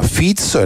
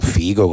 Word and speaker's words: Fico: Prima pagina del Corriere della Sera si apre con Robert Fico, e non Figo come ci sarebbe piaciuto pronunciare a noi.